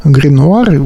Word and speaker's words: Grenouer: несмотря на английский язык, это все-таки российская группа, питерская группа Гринуар. Вот как она пишется, тут произношение и написание различается.